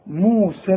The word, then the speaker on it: موسى: موسى الكتاب، الله، طب ما هي موسى فيها ي، والي بتتحول إلى ألف في النطق. موسى، أنا لغيت الي لما وصلت قلت موسى الكتاب، الحكم ده هتاخده فيما بعد، لكن إذا وصلت إلغي الياء مؤقتا. موسى الكتاب، وكأن الياء غير موجودة.